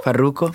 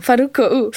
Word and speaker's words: Farruko: Farruko. – Farruko.